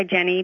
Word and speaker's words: Jennie: When I was a little girl, I always used to go just by Jenny because I thought it was easier to spell. I spell it J-E-N-N-I-E.